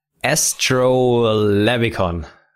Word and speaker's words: Astrolabicon: Astrolabicon. – Right. – Is that right? – Yeah. – Properly pronounced? – Astrolabicon.